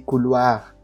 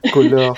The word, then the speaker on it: couleur: Parce que si tu te souviens, souvent je dis couloir à place de couleur.